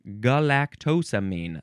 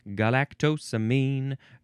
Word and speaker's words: galactosamine: We now have galactosamine. Galactosamine. That's how you say it. Galactosamine.